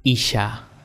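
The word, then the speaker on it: ish (איש): Varón se dice ish. I-S-H.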